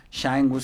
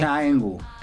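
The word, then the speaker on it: Cha Eun-woo: Shang Woo se escribe Cha, como de cha-cha-cha, E-U-N, Woo con doble O, okay. – Shang Woo.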